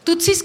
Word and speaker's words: Tutsis: Tutsis, como la paleta Tutsi Pop, igualito, así se escribe.